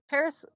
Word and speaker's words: Parris: Paris, you know, kind of like the city, but not really. It's spelled P-A-R-R-I-S. So like Paris, but not half as cool.